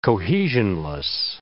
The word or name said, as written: cohesionless